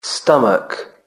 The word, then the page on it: stomach: British English